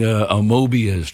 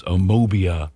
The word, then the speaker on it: amoeba: Amoebia was what she said, Celeste, on the early editions when I was trying to shovel down some eggs before I left the house with the news on. Amoeba, as she meant.